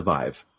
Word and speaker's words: Vive: You called it the Vive. Is that the official title? – That is the official pronunciation of the HTVIVE, the Vive.